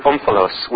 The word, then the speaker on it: omphalos: I actually use that in the mythic place unit with the Greek word omphalos, which means belly button.